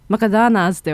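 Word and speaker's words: McDonald's: 謎にマクドナルドだけは英語の発音の仕方やね、マクドナーズって言わなあかんねん、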